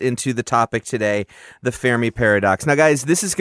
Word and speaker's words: Fermi: There's one not too far from us here in Chicago, mm-hmm, called the Fermi Accelerator. – Yeah. And you said it right. Fermi. I say Fermi all the time. Fermi Lab. It's Fermi Lab.